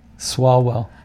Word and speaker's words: Swalwell: Swalwell.